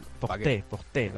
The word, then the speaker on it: Col du Portet: ¿Cómo se dice? – Col du Portet. – Col du Portet. – Porté.